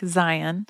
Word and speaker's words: Zayin: Our letter for today is the Hebrew letter Zayin, which is spelled Z-A-Y-I-N, but it's pronounced like Zion.